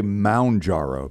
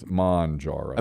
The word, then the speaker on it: Mounjaro: I've heard in some of the commercials, they almost say Mounjaro because it's M-O-U-N. J-A-R-O. – Yeah. That's how people pronounce Mount, yeah. But it's Manjaro. – I put it into my body and I still can't say it.